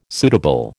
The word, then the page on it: suitable: American English